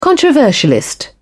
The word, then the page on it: controversialist: British English